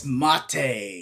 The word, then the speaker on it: mate: But it's actually not mate. – Oh. – Oh. – It's mate.